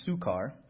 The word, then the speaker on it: Sychar: So he came to a town of Samaria called, it's, called, it's pronounced Sychar, near the field that Jacob had been given to his son Joseph.